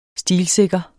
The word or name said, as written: stilsikker